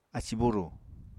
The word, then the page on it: ací-búrù: Apatani